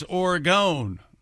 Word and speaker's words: Oregon: They said it's Oregon. Oregon. Oregon. Like hexagon. Pentagon. Oh, Oregon. – Oregon. – Oregon. Oregon. Is it like Oregon, like Wilhelm Reich in the orgasm theory?